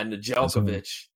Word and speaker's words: Nedeljkovic: Nedeljkovic? – Yeah, Nedeljkovic.